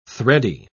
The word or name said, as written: thready